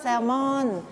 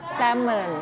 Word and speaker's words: salmon: salmon แต่ภาษาอังกฤษอ่านว่า salmon ไม่ออกเสียง l นะ salmon นะคะภาษาไทยใช้คำว่าปลา salmon แต่ภาษาอังกฤษไม่ใช่ salmon นะคะโอเคต่อมาก